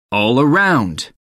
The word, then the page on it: all-around: American English